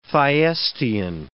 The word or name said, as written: Thyestean